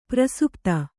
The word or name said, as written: ಪ್ರಸುಪ್ತ